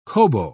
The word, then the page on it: kobo: British English